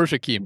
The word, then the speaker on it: Shaquem: I didn't pronounce pronounce that correctly. – Or Shaquem. – Shaquem. Shaquem or Shaquille. Yeah. Is that right? Did I get that right? – Now you did.